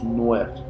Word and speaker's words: Nuer: Hace rato lo dije mal, es, son los Nuer, N-U-E-R, ¿vale?